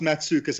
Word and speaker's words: Matzukis: Yeah, it's pronounced Matzukis, and don't worry, man. I'm, I'm very used to the mispronunciation.